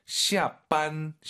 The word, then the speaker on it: 下班: 힘을 좀 빼고 하시는 게 좋을 것 같아요. 아하. 너무 쌍비읍이 강하면 좀 별로예요. – 要更自然一点. – 해볼게요. – 下班. – 이거 이거. 어? 지금 왕 선생님도 쌍비읍 아닌가요? 어, 비슷해요. 근데 완전 우리가 알고 있는 반 이건 아니에요. 비읍과 쌍비읍의 중간, 시아반 이게 좋을 것 같습니다.